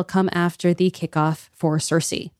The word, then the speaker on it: Circe: The accepted pronunciations, though, are to do the C's as S's. That's why you hear Cicero. That's why you hear Circe.